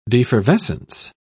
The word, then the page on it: defervescence: British English